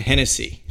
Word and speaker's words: Hennessy: Hennessy. – Correct. – Just like the drink.